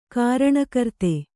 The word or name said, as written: ಕಾರಣಕರ್ತ